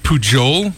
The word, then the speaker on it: Pujol: Pujol. P-U-J-O-L. – Yeah, I like it. – Pujol. – Pujol, yeah. – As in Albert Pujols from the Los Angeles Angels.